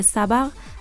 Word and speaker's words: سبق: از این احادیث چنین استفاده می شود که سبق اگر با فتحه دو حرف اول تلفظ شود